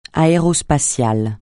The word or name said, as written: aérospatial